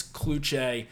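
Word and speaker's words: Kluch: You probably have been saying his last name wrong for the past few years. It is Chris Kluche, and this guy has been on an absolute tear since graduating from UNC.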